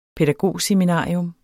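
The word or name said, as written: pædagogseminarium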